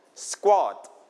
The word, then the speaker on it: squat: Yeah, we call squat. Everybody repeat after me, squat. Okay, squat. In English, we pronounce k as k when it is with s.